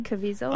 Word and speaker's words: Caviezel: Caviezel.